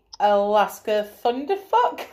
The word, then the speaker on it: Thunderfuck: But I did see Alaska Thunderfuck.